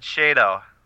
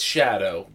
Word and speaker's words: Shado: Is it shadow or is it shado? – It's shadow, based on... As long as they're using the same pronunciation that Arrow uses.